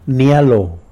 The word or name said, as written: nilo